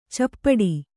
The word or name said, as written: ಚಪ್ಪಟ್ಟೆ